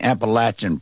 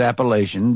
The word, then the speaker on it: Appalachian: This death-defying adventurer axed his way through the Appalachian Mountains to say, and by the way, I'm pronouncing Appalachian properly. I know you were taught in public school it's Appalachian, but you go out there and it's Appalachian.